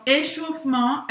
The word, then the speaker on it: échauffement: Então, échauffement é aquecimento, literalmente, tá? Literalmente significa isso. Échauffement, échauffement. Presta atenção porque esse E no meio, esse E no meio, ele praticamente não é pronunciado.